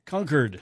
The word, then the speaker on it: Concord: New Hampshire, Massachusetts, and California are among those who favor something closer to the words of Julius Caesar, Concord. – Concord. C-U-R-D.